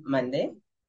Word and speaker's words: mande: Nunca dijiste que en casa y te contestaron con no se dice qué, se dice mandé.